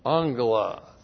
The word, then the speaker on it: angelos: And then the genitive plural of angelos. Looks like that in the Greek, A, two gammas together. The first is pronounced like an N, G-E-L-O-S. Angelos.